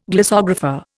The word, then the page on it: glossographer: Indian